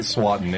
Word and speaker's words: Swanton: Swanton. It rhymes with rotten.